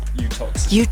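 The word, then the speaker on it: Uttoxeter: Utoxeter. – Utoxeter. That's the hardest word I've ever read in my life. Utoxeter.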